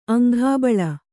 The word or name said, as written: ಅಂಘಾಬಳ